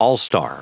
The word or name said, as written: all-star